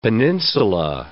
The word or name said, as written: peninsula